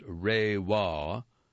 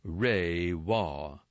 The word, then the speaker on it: Reiwa: Under unveiled, Reiwa, as the name of Japan's new imperial era, Meibu pronounced Reiwa, announced by the Japanese government on April 1st. It officially translates as, quote, "beautiful harmony," unquote. Reiwa, spelled R-E-I-W-A.